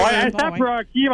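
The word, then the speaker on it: A$AP Rocky: On le prononce-tu comme ça, lui? Ou... – Ouais, ASAP Rocky.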